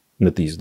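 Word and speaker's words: NetEase: Пару слов про NetEasy. NetEasy, да, правильно говорить.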